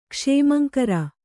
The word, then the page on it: ಕ್ಷೇಮಂಕರ: kṣēmaŋkara